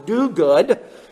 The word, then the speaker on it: Duguid: One man, I think his last name is pronounced Duguid. It's spelled D-U-G-U-I-D. I think it's pronounced Duguid, makes this observation relative to N.T.